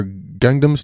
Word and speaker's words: Gangnam: I think Gangman style or Gangnam style. I don't. It, it it's pronounced or not pronounced. It's spelled I think Gangman but it was pronounced Gangnam Style.